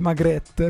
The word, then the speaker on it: Magritte: La pronuncia è Magritte, ne sono sicuro.